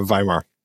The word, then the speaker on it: Weimar: but is it the Weimar era or Weimar? Am I saying it wrong? – Yes, it's a V sound at the beginning of the name.